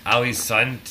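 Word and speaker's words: Ålesund: Then this new band, and I believe it's pronounced Ali's Sund.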